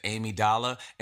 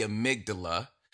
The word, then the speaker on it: amygdala: I pronounced that incorrect. I think I said amygdala. And so I don't want anyone to think that is the way to pronounce that. It is the amygdala.